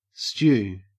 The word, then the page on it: stew: Australian English